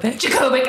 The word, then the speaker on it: Jacobik: I am with my new friends, Michelle Jacobic. Did I say that right? – Jacobic.